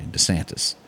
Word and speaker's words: DeSantis: Now, I have to take up for Ron DeSantis on this. Did I say that right? – Yep. – Okay, DeSantis.